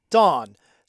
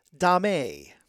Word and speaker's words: domme: So that's the D-O-M-M-E, mm-hmm. which usually means a female dom. So, and like I said, some people call it dame, some people call it dom, some people like to just use the word dom instead of dame.